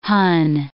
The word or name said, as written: hon